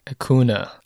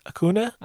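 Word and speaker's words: Acuña: It's A-C-U and then squiggly line over the N, A. – Acuna. – Acuna? – That's my guess.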